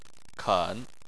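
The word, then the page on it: ken: tone 3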